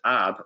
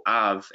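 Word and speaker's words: ab (אב): and you pronounce it ab or av, as they say it.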